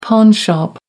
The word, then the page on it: pawnshop: American English